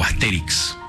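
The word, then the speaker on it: Astérix: el guionista de Asterix, o Asterix, porque tiene el acento en la E.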